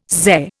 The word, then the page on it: Xe: Indian